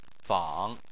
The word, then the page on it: fang: 3